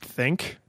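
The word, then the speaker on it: Thync: So I guess this is pronounced think. It's T H Y N C.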